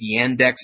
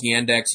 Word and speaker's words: Yandex: So, for example, in Russia, I think it's Yandex is how you pronounce their version of Google is Yandex.